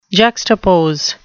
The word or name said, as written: juxtapose